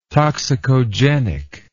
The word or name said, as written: toxicogenic